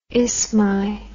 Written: isthmi

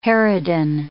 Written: harridan

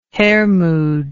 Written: Hermod

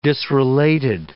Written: disrelated

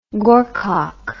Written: gorcock

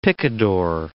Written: picador

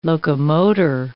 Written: locomotor

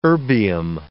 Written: erbium

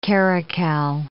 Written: caracal

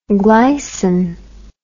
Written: glycin

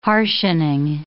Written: harshening

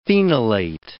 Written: phenolate

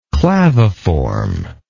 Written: claviform

